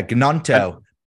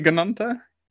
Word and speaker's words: Gnonto: Um, is it Notto? I think that's how you say his name. – Yeah, Gnonto. – Is it Gnonto? – Interesting.